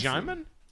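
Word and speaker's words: Djimon: Digimon. – Jaimin Hansu. – Jaimin Hansu. – Jaimin. – Like Simon. – You don't pronounce the D.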